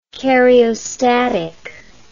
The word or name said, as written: cariostatic